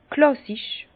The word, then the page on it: klàssisch: Bas-Rhin; Herrlisheim